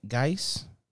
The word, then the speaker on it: Gaetz: So we had a Republican uh, Matt Matt Gaetz. Yeah, that's how you say his name.